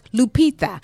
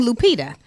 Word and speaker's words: Lupita: And congratulations again to Lupita, which is really pronounced Lupita. If she would say her name in the correct, you know, what do you call that? – Dialect. – Then we would have known she was Mexican. But she's saying Lupita. It's not Lupita.